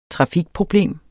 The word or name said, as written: trafikproblem